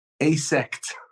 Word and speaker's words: AASECT: AASECT.